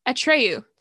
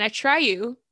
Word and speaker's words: Atreyu: Atreyu. Thank you. And Travis Barker dropped a new single, Warrior. And then Atreyu, did I say that right? – Mm-hmm.